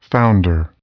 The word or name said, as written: founder